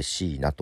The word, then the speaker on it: 4NA: ちょっと新しめの 2020 年に、デビューしましたシーナというアーティストの 6 月 15 日に出したシングル流したいと思います。シーナでそぐわな。はい、シーナのそぐわなという曲です。 4、数字の 4 と NA、 4NA と書いてシーナと読むアーティストですけども、